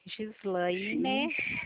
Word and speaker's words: Gislaine: Voto com G. – Com G. Gislaine.